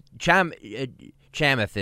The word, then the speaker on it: Chamath: I'm probably not saying that right, You're definitely not saying that right. – Shamath Palihapitiya. How do you say it? – It's Chamath is how you say it.